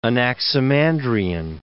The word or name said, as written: Anaximandrian